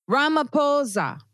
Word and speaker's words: Ramaphosa: Ramaphosa. Okay, so yeah. Ramaphosa. Yeah. – Like pause. Like pause with an A. Like P-A-U-S-E. And then an A. That's how you pronounce it.